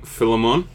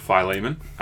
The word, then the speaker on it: Philemon: It's from Philemon, if you like to pronounce it like Pokemon, or it's from Philemon. Other people pronounce it that way.